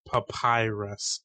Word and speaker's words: papyrus: Okay, for the record, you guys are right. It's pronounced papyrus.